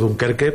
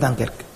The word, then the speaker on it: Dunkerque: Yo no tengo muy claro que cuando Héctor Rodas va a ver a tu equipo, yo lo pronuncio mal, yo digo Dunkerque, pero no es Dunkerque, tú lo pronuncias bien. – Es Dunkerque.